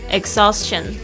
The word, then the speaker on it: exhaustion: The antidote to exhaustion isn't rest, it's nature。好的，我们今天要记忆的两个单词，第一个就是 antidote，a n t i d o t e，就是解药、矫正方法、解毒剂，antidote。 Number two, exhaustion, exhaustion，枯竭、耗尽、精疲力竭。 E X H A U S T I O N，注意一下那个 H，中间的 H 是没有发音的。